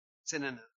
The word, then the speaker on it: synonym: I always say that wrong, right? Did I say it wrong? Synonym, synonym. I always want to say cinnamon.